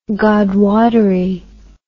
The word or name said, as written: godwottery